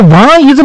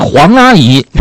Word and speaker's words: 王: 好嘞，这个王阿姨这么简单的词儿还有人发错啊？你们这个读书怎么读的？这个就还有人发黄阿姨，有的吗？是三横王的王阿姨啊。